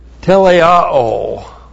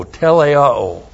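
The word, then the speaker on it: teleioō: The word made perfect is teleao. T-E-L-E-I-O, long O, teleao. It is from this verb teleao that we have teleos, T-E-L-E-I-O-S, and that is spiritual maturity.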